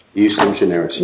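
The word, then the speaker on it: ejusdem generis: Well, so here's my question for you, which is in SGI and in sports graphics, they relied on, and I'm going to pronounce this wrong, despite my clerk actually giving me the phonetic pronunciation, eustem generis. – Eustem generis, yes, eustem generis, all right.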